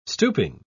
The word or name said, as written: stooping